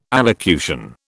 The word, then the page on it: allocution: British English